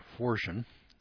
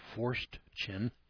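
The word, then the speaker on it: Forstchen: Forshen. An odd spelling name here. It's an odd name. Here's the way to spell it. It's F-O-R-S-T. C-H-E-N, William R. Forshen, with a foreword by Newt Gingrich.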